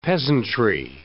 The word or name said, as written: peasantry